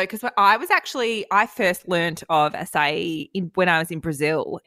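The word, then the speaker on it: açaí: Someone sent her pronunciation through and they were saying acacia, which my ex-boyfriend actually used to do. He'd be like, can I get an acacia bowl? And I'm like, it's an acai bowl.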